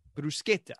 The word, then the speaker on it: bruschetta: So what is the proper pronunciation? – Bruschetta. – Oh, it is? So it is it's a hard S-C-H. Yep. Interesting. Yep. – But and also E is A. So bruschetta. Bruschetta.